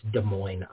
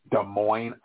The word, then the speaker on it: Des Moines: And I'll just let you know, just in respect to Thomas Bulmer, when you say Des Moines, you do not pronounce the final S. So it's Des Moines, Iowa. – Des Moines, Iowa.